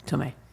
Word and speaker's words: Tomei: Is it Tomei? – Tomei. Not, I mean, I just know that from Seinfeld.